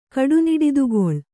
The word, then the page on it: ಕಡುನಿಡಿದುಗೊಳ್: Kannada